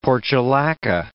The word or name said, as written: portulaca